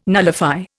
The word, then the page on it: nullify: Indian